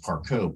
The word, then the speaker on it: Parcaut: It's, I'm Ed Parcaut, P-A-R-C-A-U-T.